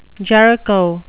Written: Jericho

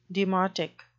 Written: demotic

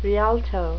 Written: rialto

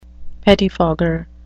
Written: pettifogger

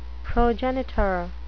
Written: progenitor

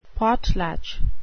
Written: potlatch

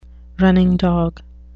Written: running dog

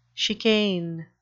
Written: chicane

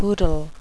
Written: boodle

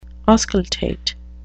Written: auscultate

